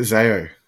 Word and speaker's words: Zao: Is it Zao? – Yeah.